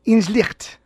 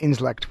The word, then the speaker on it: Inslicht: Inslicht. – Mm. – So you know the ha there should actually, that's the you know the right pronunciation. I say Inslicht when I pronounce it myself in English.